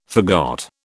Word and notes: forgot: British English

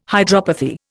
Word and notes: hydropathy: Indian

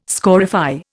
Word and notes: scorify: Indian

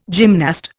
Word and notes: gymnast: Indian

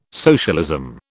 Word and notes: socialism: British English

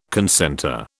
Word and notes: concentre: British English

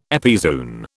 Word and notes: epizoon: British English

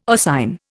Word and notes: oscine: Indian

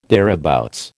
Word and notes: thereabouts: American English

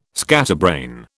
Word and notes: scatterbrain: British English

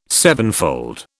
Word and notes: sevenfold: British English

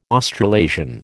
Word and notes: Australasian: American English

